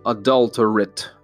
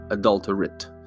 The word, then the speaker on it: adulterate: А, произносить нужно правильно, это прилагательное, поэтому говорим adulterate. Не rate, а adulterate.